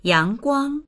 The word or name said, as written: yang2guang1